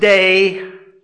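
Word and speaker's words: day (δεῖ): Now that word, it's pronounced day.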